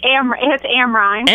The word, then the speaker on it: Amrine: It's Amrine.